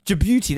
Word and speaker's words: Djibouti: How this is like Djibouti and like, you know, Niger and Djibouti, that's how you say it.